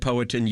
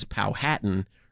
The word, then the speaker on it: Powhatan: Matthew said Powhatan, you said Powhatan. That does seem to be the accurate way to pronounce the word. Many people see it and read it as Powhatan, rhyming with Manhattan.